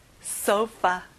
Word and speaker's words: sofa: 여기 f 발음 잊지 마세요. sofa.